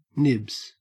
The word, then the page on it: nibs: Australian English